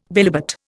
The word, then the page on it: bilobate: Indian